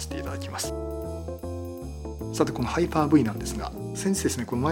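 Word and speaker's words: Hyper-V: もちろんこれ海外の方はね V なんていうことなくてですね、ハイパー V が正しい発音となります。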